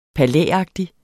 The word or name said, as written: palæagtig